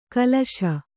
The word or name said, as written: ಕಲುಷ